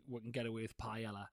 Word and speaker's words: paella: I think it's probably paella. – Paella. – Because the double L makes a Y sound.